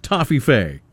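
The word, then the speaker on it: Toffifee: It said, we had a Toffee Fee challenge between our radio stations the other day, but there was a disagreement on how to pronounce it. We say Toffee Fee, others say Toffee Fee, and then there's one weirdo who pronounces it Toffee Fee. Our station won the eating challenge, but please say we won the pronunciation too. Their reply? Are you ready? – Yes. Dun, dun, dun. – Yes, you won. It's pronounced Toffee Fee.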